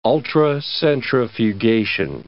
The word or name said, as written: ultracentrifugation